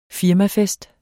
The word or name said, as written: firmafest